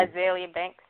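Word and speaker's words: Azealia Banks: I can't pronounce it. Azalea. – That fixed my life, lady. – Azalea Banks. – There you go.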